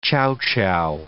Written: chowchow

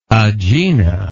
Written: agena

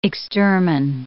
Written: extermine

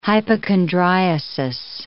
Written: hypochondriasis

